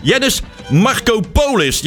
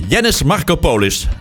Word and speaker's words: Yannis Markopoulos: Mag trouwens niks zeggen, ik kan het zelf ook bij kaas niet uitgesproken krijgen. Yannis Markopoulos. Ja, zo heet hij. Yannis Markopoulos.